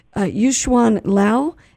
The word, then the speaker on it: Yu-Hsuan Liao: Yu-Hsuan Liao.